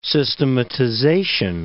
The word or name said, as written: systematization